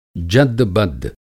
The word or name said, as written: जद्द-बद्द